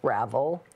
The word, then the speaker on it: Ravel: My name, by the way, is pronounced Ravel.